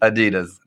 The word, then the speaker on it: Adidas: Adidas, oh. But like properly pronounced. Sorry, I got corrected in Europe a lot. But if you're that brand. – They don't call it Adidas in Europe? – No, it's Adidas.